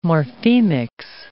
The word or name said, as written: morphemics